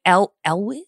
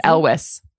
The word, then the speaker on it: Elwes: But my MVP, you just pronounced this like Carrie Elwes? – I think it's Elwes.